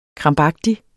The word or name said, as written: krampagtig